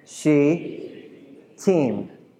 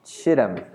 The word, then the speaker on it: Shittim: So let's talk about how to pronounce this Hebrew word. Repeat after me. She. – She. – Team. Ready? Say it again. She. Team. It's not Shittim. It's she team.